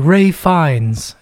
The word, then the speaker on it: Ralph Fiennes: It came up as Ralph Fiennes, and I was like, that's not his fucking name. And then I Googled the pronunciation, and it was like, Ray Fiennes. And I was like, oh, okay, yeah, that's the name I remember.